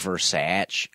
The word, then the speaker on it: Versace: It's like, Versace. – Versace. – Versace. – Like, it's just, it's Italian, you fucks.